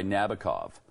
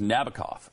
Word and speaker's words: Nabokov: There's that line in the song that says, "Like the old man in that book by Nabokov." You know that line? – Mm-hmm. – But Sting mispronounces the word or the name of the author. It was Nabokov, and I always used to think, why is he saying Nabokov?